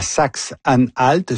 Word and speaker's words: Saxe-Anhalt: Donc, élection importante demain dans la Saxe-Anhalt. Je prononce bien, oui? – Parfait.